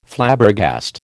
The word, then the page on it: Flabbergast: American English